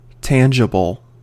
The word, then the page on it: tangible: American English